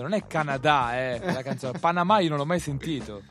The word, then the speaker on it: Panama: Che qua si dice con l'accento sulla ultima A, qua è a Panama. Panama.